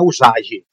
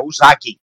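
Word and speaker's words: usagi (うさぎ): A, a propósito, aquela polêmica de, de, como se pronuncia coelho em japonês é com, é com gi, tá? Não é usagi, é usagi.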